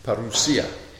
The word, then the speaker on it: parousia: Parousia.